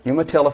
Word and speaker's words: Umatilla: Umatilla, Florida.